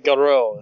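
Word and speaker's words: Garou: Goro is how you pronounce it. It's G-A-R-O-U.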